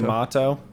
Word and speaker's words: Yamato: Yeah, it's Yamato. – Yamato. – Yeah. – Yamato. Yeah. – We're also introduced to Yamato.